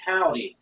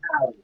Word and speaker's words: Haude: It's actually Howdy. It looks like Hod, but it's actually Howdy, so.